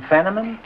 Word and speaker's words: Fenneman: Mr. Fiddeman. – Mr. Fiddeman? You mean Fenneman? – That's right.